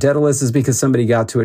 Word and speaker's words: Daedalvs: It's pronounced Daedalus. It's spelled Daedalvs. The reason is because in Latin, U's used to be spelled like V's, and also because it was easier to get that handle on Diablo 2. – Yeah. – Than just with a U.